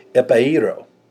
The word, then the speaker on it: epairo: Now, this word for lifted up is a Greek word, and it's a word, it's spelled E-P-A-I-R-O. Epairo. Epairo. E-P-A-I-R-O.